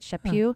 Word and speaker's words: Chaput: In an article by CNA, EWTN News, talking about Archbishop Chaput. Am I saying that right? Chaput. – Mm-hmm.